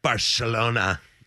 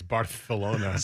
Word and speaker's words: Barcelona: We're over here in a playoff beating, Barcelona. Down 4-0. – I think you said that wrong. I think it's Barcelona. – Sorry, I forgot the lisp.